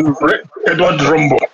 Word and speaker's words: Edward Rombo: Simple name, Edward Rombo.